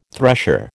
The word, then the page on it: thresher: American English